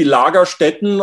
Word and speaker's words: Lagerstätte: I maybe I'm going to mispronounce this, but it's the word Lagerstadt. Did I did I say that right? – Yeah, the German word would be Lagerstätten or Lagerstätte in singular, which is a, a fossil locality with special preservational circumstances yeah, those are supposed to be huge localities of fossils.